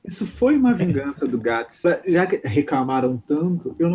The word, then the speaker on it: Gatiss: It's pronounced Gatiss and no other way. Just think gay. I always do.